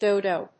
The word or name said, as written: dodo